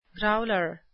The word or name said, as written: growler